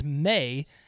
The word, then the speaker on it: Mahy: My last name's May, but it's spelled M-A-H-Y.